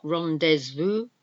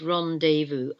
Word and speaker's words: rendezvous: Again, comes from a French word. We don't pronounce the Z in it. It's not rendezvous. It's rendezvous. R-E-N-D-E-Z. V-O-U-S.